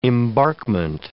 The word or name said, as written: embarkment